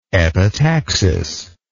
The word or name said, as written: epitaxis